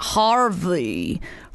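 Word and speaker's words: HRVY: Harvey.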